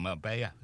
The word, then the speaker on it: Marbella: Echo special report reports that Spanish police thought wanted criminal Philip Foster was in Marbella, or Marbella, you pronounce it, don't you? That's how you pronounce it.